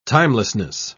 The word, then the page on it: timelessness: British English